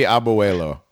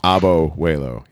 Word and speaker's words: Abuelo: It would be Abuelo, but either way. Anyways. There's another syllable. Her Abo Waylo. – Abo Waylo, yes.